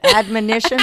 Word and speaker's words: admonition: So then the third, he says, is that TOB is not primarily an abnomission. Am I saying that right? – No. – How do you say it? – Admonition. – Admonition. Thank you.